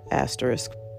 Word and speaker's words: asterisk: And that's how you say it. Asterisk. I can't stand when people say asterisk. It's not the word.